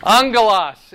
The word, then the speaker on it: angelos: Angelos. Yeah, it's just angel. So number seven is angel. We pronounce it with a soft G. There's no soft G in the Greek. It's angelos.